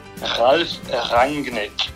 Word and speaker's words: Ralph Rangnick: Ralph Rangnick.